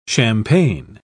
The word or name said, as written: champagne